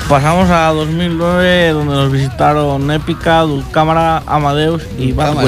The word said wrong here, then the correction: Dulcamara: ¿Para qué me cómo se pronuncia? Dulcamara. – Dulcamara. Has dicho, Dulcámara Porque está dormido, eh.